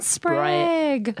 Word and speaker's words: Sprague: A Human Approach to an Alien Phenomenon by Ryan Sprague. Sprague? That's how you pronounce it? – You got it. – Nice. Sprague. – Prague with an S.